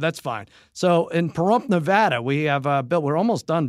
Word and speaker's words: Nevada: He says I say Nevada. And it's Nevada, and I got a couple of letters saying it's Nevada.